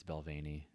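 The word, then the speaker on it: Balvenie: Pronounced Belvaney? – Yeah, it's Belvaney.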